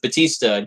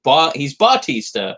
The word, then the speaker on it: Bautista: it, you know, like we did with Batista. He's Bautista, and we had to change it to Batista. So it's easier for, you know, certain tongues to pronounce it, as we'll just leave it at that.